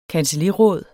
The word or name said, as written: kancelliråd